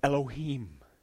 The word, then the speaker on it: Elohim: The word looks like this in English. E-L-O-H-I-M. It's pronounced this, Elohim. Let's just say it, will you?